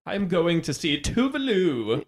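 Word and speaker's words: Tove Lo: So I actually have had Tovlo on repeat a little bit. – Someone told me that it's pronounced Tuvlu because she's Swedish, right? – Tuvlu.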